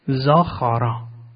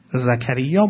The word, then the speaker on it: زکریا: تلفظ این کلمه رو ما الان با ساختار فونتیک عربی داریم و الا در حقیقت این کاف خیه، زاخارا. گاهی نام زاخاروف رو الان در اصطلاحات فعلی داریم. زاخارا همان زکریاست. در عبرانی زاخارا و در عربی زکریا با تلفظ زکریا.